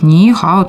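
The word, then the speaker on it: «нихао» (你好): поэтому первый тон для первого слога, он переходит во второй тон, и получается первый слог мы произносим вторым тоном, как с удивлением «ни», а второй слог произносим третьим тоном, уже нисходящий и восходящий «хао». В итоге мы получаем «нихао».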